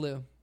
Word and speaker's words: Liu: Is it Liu? – It's Bing Liu. – But it's L-I-U is how it's spelled.